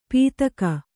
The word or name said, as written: ಪೀತಕ